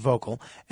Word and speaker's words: Vocl: V-O-C-L, but it's pronounced vocal.